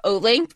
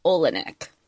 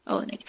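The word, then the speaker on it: Oleynik: I always called her Olink, but then I actually looked phonetically at the spelling. I don't know if I'm saying it right, but it's like Olenek. – Olenek.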